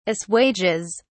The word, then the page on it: assuages: British English